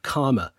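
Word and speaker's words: Caldmore: He let his mask slip by asking directions for Coldmore Green, using the correct but little-known pronunciation of Karma.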